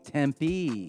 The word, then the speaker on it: Tempe: You got to say it like a real Arizonan. – Tempe. – Tempe.